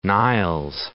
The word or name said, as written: Niles